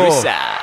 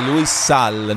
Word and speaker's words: Luis Sal: Luisa. – Pazzesco, Luis Sal, non tutto attaccato nella, neanche nella pronuncia. – Eh, Luis Staccato H-A-A-L.